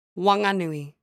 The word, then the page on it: Whanganui: Local Dialect